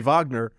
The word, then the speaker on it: Wagner: Is it pronounced Wagner or Wagner? – You're asking me, so you know the answer is going to be Wagner. The Wagner Group.